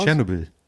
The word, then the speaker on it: Tschernobyl: Tschernobyl.